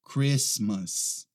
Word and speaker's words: Christmas: Muito bem, a próxima é esta aqui, ó, Christmas, Christmas. Essa aqui não é Christmas, a gente não pronuncia aquele T ali, tá? É Christmas, Christmas.